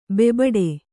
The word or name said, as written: ಬೋಬಾಟ